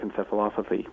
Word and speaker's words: encephalopathy: He dedicates his brain, and his family finds out that he's diagnosed with CTE, chronic traumatic encephalopathy, which I think I've mispronounced. – No, I think you got it.